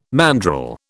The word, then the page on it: mandrel: British English